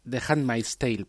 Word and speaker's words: The Handmaid's Tale: Pues eh, The, Handman, Handman's, The Handmaid's Tale, perdón, porque la pronunciación ahí me ha salido mal.